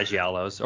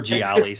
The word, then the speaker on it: Gialli: Or is that how you say it? – Yeah, Giallos or Gialli. Some people don't. Some people will argue. They're like, Giallis. It's gotta be Giallos.